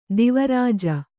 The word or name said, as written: ದೇವರಾಜ